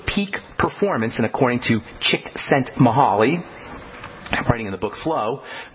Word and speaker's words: Csikszentmihalyi: His name is pronounced Csikszentmihalyi.